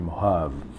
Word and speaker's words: Mojave: Ça s'écrit Mojave avec un J, mais ça se dit Mojave.